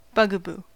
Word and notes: bugaboo: American English

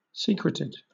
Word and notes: secreted: British English

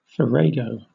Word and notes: farrago: British English

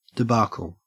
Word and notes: debacle: Australian English

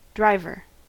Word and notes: driver: American English

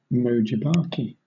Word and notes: venomous: British English

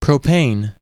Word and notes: propane: American English

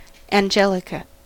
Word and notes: angelica: American English

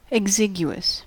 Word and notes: exiguous: American English

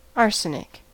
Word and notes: arsenic: American English

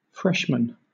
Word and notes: freshmen: British English

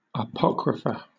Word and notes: apocrypha: British English

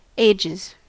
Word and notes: ages: American English

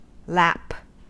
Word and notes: lap: American English

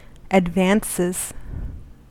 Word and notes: advances: American English

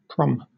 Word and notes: prom: British English

